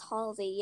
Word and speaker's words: Halsey: Am I pronouncing the name right? Halsey? – Yeah, I think it's Halsey, yeah. That's how they say it on the radio anyway.